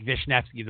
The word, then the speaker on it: Wisniewski: Now it's spelled Wisniewski. There's not even a V or an F or nothing, but that's how you pronounce it. So everybody goes, Wisniewski? She's like, Vishnevsky.